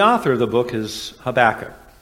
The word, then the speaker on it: Habakkuk: The Hebrew pronunciation of his name would be something like Habakkuk. Yeah, that's exactly how it sounded. If you have a cold, it's easier to say, because it's a Habuk. But we are going to use the English pronunciation for the same reason that my son is David and not David, which would be more the Hebrew way to do it.